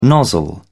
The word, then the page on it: nozzle: British English